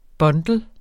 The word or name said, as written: bundle